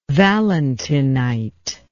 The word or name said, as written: valentinite